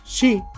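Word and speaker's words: Shi (四): Sucede que la pronunciación del número Shi es exactamente la misma que la palabra muerte.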